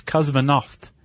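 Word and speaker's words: kosmonaut (космонавт): Which, by the way, I was like, how do you pronounce that in Russian? And apparently it's very close. It's kosmonaut.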